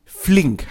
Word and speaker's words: Flink: Und es geht um Flink.